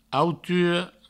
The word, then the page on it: autura: auturo; Provençal